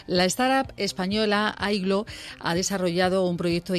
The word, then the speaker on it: Aygloo: Ignacio, dime, por favor, antes de nada, porque como se escribe A-Y-G-L-O-O, si lo he dicho bien, o pronúncialo tú, por favor. – Aiglo.